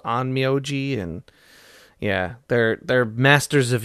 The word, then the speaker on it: Onmyoji: All her many powers combined imply she's an Onmyoji, I think I said that right? – Yeah.